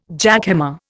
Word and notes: jackhammer: Indian

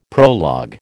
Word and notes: prologue: American English

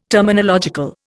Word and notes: terminological: Indian

